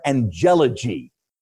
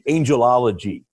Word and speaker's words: angelology: Some people like to pronounce it angelology, but you know that's not really how we we we, we always put the accent on the penult, right? of the syllable. So properly in English, the study of angels is called angelology. But that logy or that ology is exaggerated a little.